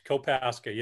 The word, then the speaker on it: Kopaska: Kopaska.